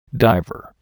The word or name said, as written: diver